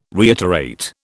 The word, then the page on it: reiterate: British English